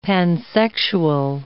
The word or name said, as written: pansexual